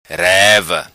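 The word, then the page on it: Reva: patua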